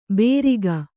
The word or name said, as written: ಬೇರಿಗ